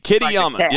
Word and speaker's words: Kitayama: Kitayama, Kitayama.